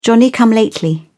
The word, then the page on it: johnny come lately: British English